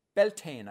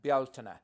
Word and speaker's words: Bealtaine: And we call in America the other it's called Beltane, I think they call it. Yeah, Beltane. It's like kind of an anglicized version, and that we pronounce that as Bealtaine, and that comes from Baal Tinna.